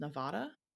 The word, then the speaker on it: Nevada: So one thing I want to get out of the way really ahead of the game is that it is pronounced Nevada. – Not Nevada. – Keep that nasally A to yourself, folks. And that's mostly because the word Nevada comes from Spanish and it literally means snow-capped.